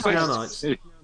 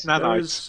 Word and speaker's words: nanites: Is it nanite? Which one? How am I saying it wrong? How is it? – It's nanites. – In real life, it's nanites.